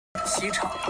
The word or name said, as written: xi chang